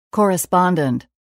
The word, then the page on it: correspondent: [kɔ́ːrəspɑ́ndənt]